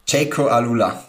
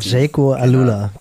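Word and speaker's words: Jayco AlUla: Mir hast du es hier geschrieben, du bist gerade in der Höhe mit den Jungs von, wie werden sie ausgesprochen, das australische Team? – Jaco Alula, das war dort. Jaco Alula, genau.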